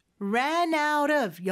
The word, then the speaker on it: ran out of: 오늘은 ran out of, 여기 연음을 주의하시면 돼요. ran out 하면서 T 발음은 모음 소리 사이에 껴서 리을처럼 부드럽게 발음이 되죠. ran out of. 그리고 dish soap.